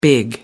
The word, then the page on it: big: British English